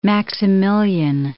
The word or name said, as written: Maximilian